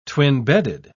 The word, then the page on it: twin-bedded: British English